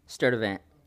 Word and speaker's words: Sturtevant: Sturtevant. – Sturtevant. – Sturtevant. – Sturtevant. – Just how it's spelled.